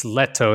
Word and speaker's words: Lehto: I think it's Lehto is the Finnish pronunciation, but I guess Lehto.